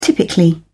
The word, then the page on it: typically: British English